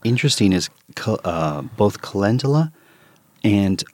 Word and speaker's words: calendula: Calendula.